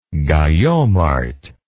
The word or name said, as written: gayomart